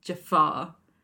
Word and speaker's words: Jafar: And Jafar is, as opposed to being J-A-F-A-R, is J-A apostrophe F-A-R. So it's Jafar. And you have to pronounce it like that instead.